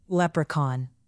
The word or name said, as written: leprechaun